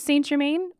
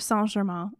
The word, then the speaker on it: Saint-Germain: You can use Saint-Germain or Saint-Germain, as it's technically pronounced. It is Saint-Germain. – It is French, actually.